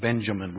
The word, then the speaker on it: Benjamin: So he changed the little lad's name from Ben-Oni to Ben-Yamin, Benjamin, we pronounce it, which means son of my right hand.